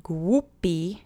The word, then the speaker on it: głupi: Let's say głupi together three times. Keep the vowels monosyllabic and the accent is on the first syllable.